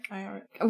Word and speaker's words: Iorek: Episode four will I envision will be us meeting Lee Scoresby and Ira Ira Iorek? Iorek.